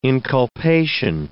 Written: inculpation

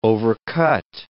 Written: overcut

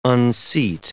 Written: unseat

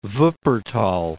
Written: Wuppertal

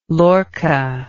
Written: Lorca

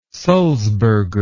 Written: sulzberger

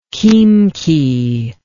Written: Khimki

